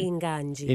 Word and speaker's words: Inganji: Is it Inganji? Am I pronouncing that right? – Inganji. – Inganji.